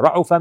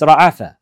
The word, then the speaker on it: Ra'afa: It's not Ra'ufa, it's Ra'afa. But in reality, the language has come with two different pronunciations. But Ra'ufa is a weak manner of saying this verb. And the strong and the preferred way to say it is Ra'afa.